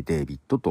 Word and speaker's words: d4vd: D4VD と書いてデイビットと読みますね。